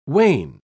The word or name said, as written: wain